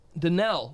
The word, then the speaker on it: Danelle: Danielle, oh, no, wait, Danelle Bumstead. Am I saying it right? – Yeah.